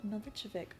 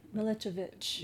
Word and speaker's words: Milicevic: Milicevic. – Yeah. – Milicevic. – The C's are C-H's. – Okay. – They're supposed to be spelled with like little U's over the C's, but we didn't, when my family came, they didn't change the...